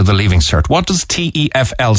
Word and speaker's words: TEFL: It's pronounced TEFL, I think. Am I correct, Katie? – Yes, that's correct. The TEFL.